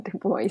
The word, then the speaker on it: Dubois: 对，他们当地人都念 DuBois，所以对对对对，我就照这样念，当地人怎么念就怎么念嘛，嗯嗯，管管管他法文个屁。